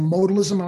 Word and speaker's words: modalism: am I saying modalism? Am I saying that right? – That's right.